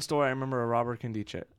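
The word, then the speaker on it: Nkemdiche: Is that how you say it? I always forget. – Robert Kemdiche, yeah.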